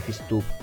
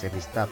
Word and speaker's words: fstab: FSTUB, aunque se escriba así, FSTUB, es FSTAB pronunciado bien, del kernel.